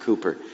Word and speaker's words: Cowper: William Cowper, his name looks like it says Cowper, but you pronounce it Cooper.